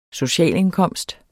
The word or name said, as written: socialindkomst